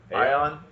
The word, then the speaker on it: Aion: But I did pick up another NCSoft game called, I'm going to say it wrong, Aion? – Aion? – Aion? – That's how I always pronounce it. – It's like Ion with an A in the beginning. – Yeah, A-I-O-N.